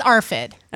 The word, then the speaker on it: ARFID: ARFID or AFRID or, you know, that thing. – Yeah, can we learn how to say it? – No. – I think it's ARFID. Rebecca's looking up. It's ARFID.